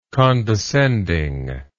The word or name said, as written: condescending